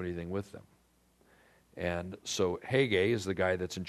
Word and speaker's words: Hege: Hege is how you pronounce this guy's name, Hege, the king's eunuch, custodian of the women.